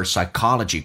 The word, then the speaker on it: psychology: A teacher was trying to make use of her psychology courses. Psychology, 心理学，你看一下，重音是在 K 上。